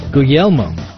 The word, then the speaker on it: Guglielmo: Guglielmo.